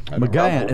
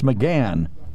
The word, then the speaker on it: McGahn: He apparently thought that McKagan, is that his name? Hagan. How do you pronounce his name? McKagan? – Who, the attorney? – The counselor to the White House. – McGahn. It's McGahn.